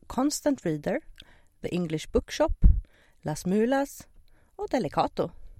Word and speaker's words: Las Mulas: Först och främst, det här avsnittet och vårt ettårskalas presenteras i samarbete med Constant Reader, The English Bookshop, Las Mulas Sauvignon Blanc. Jag är inte helt säker här på det spanska uttalet. Det var okej, säger.